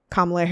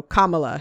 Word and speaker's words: Kamala: And so I'll say what I saw online is just to think about a comma in a sentence and then add la on the end. So, Kamala, if that helps you.